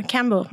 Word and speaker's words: Kambo: I was looking at your website and I know there was something, I think it was called Combo. Is that how you say it? K-A-M-B-O? – Yeah, Cambo. Mm-hmm.